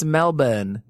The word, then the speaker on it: Melbourne: How do you say Melbourne? That's how you say it. I googled that. Calmed it. – It's Melbourne. – Melbourne. – Melbourne.